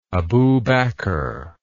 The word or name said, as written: Abu Bakr